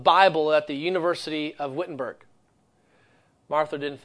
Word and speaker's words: Wittenberg: And yet he is sent to the town of Wittenberg, or you would say actually pronouncing the W, Wittenberg, pronouncing the W as a V.